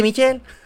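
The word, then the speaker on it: Michel: ¿No cómo se pronuncia niñita Michelle? ¿Michelle? Ese Michelle. Sonny Michelle. Así muy afrancesado.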